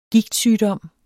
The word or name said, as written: Gigt